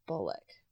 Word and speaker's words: Bullock: Bullock. Like Sandra Bullock? – Yeah. – Okay. – I'm assuming. How do you spell Sandra Bullock? – I think it's B-U-L-L-O-C-K.